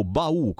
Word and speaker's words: Bau: Eh, questa è la la musica di due fratellini che si chiamano Luca e Francesco Bau con l'accento sulla U che arrivano da Asiago, dall'altopiano di Asiago ed è da decenni che suonano insieme e hanno un successo sempre maggiore.